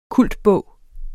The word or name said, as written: kultbog